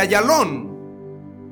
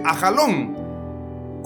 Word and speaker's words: Ayalón: Ayalón. O en otra versión dice Ajalón. Como lo quieras pronunciar, Ayalón o Ajalón es correcto.